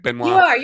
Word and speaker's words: Benoit: Arma Benoit. Am I pronouncing your last name right, Benoit? – You are.